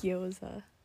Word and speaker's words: gyoza: I love how you said it. – How do you say it? – Gyoza. – Oh dear. – Goiza. Goiza is so funny. – Say it again. – Gyoza. – Gyoza. – Gyoza. – No. Gyoza.